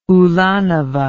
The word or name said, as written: ulanova